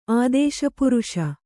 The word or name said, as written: ಆದೇಶಪುರುಷ